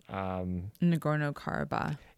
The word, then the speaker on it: Nagorno-Karabakh: Nagorno-Karabakh.